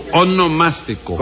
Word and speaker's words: onomástico: Sí, eso mismo, su monoelástico. – No, su onomástico.